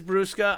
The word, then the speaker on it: Brusca: Yes, it is Brusca.